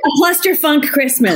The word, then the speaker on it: Cluster Funk Christmas: love, love your new movie a cluster funkin' Christmas. a, cluster, funk Christmas. – Cluster funk Christmas. yeah, just thank you. I, I added the extra syllable.